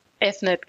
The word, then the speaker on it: Avnet: Bei Avnet, habe ich das richtig ausgesprochen, ja? – Avnet, genau. – Avnet, okay, also so Englisch dann eher ausgesprochen, okay.